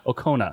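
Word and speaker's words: Okona: Robinson, on the Outrageous Okuna. Is that how you say it? – Yeah. – Okuna.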